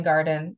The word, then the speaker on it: Weingarten: I think my handle is just so it's CW and then my last name, garden W E I N G A R T E N.